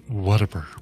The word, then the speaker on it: Whataburger: Looking at the Whataburger locations in, I don't know why, I've always heard that you pronounce it Whataburger instead of Whataburger, but I might be wrong. Maybe we need, this is a Randy thing for sure. Like, Randy would chime in on this. Oh, it's definitely pronounced Whataburger.